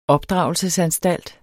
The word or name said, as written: opdragelsesanstalt